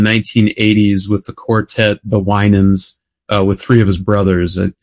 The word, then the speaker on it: Winans: And am I pronouncing that last name right? – I think it's Winans.